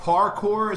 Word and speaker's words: parkour: So what is, parkour, is that how you say it? – Yeah, P-A-R-K-O-U-R.